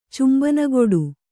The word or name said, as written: ಚುಂಬನಗೊಡು